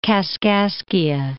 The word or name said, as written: Kaskaskia